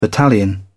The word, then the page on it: battalion: British English